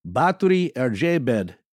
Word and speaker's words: Báthory Erzsébet: Well, in Hungarian, the pronunciation of her name would be something like Báthory Erzsébet de Etched.